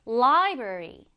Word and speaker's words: library: 자, 발음을 하실 때 강세는 앞에 강하게 넣어주세요. 그러면 library. 네, 이렇게 발음할 수 있겠죠?